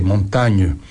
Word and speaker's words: Montaigne: Michel de Montaigne Montaigne se escribe Montaigne, con G, montaña en francés, Montaigne, Michel Miguel de Montaña, de Montaña, Miguel de la Montaña, ok.